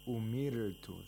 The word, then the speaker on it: أُمِرْتُ: umirtu. The ra is pronounced with tarqiq because ra is sakin and preceded by a letter with a kasra.